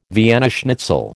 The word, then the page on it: Vienna schnitzel: American English